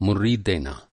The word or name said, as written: मुर्री